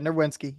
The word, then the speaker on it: Nerwinski: Yeah, Nerwinski, you got Nerwinski.